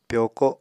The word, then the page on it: pyoko: Apatani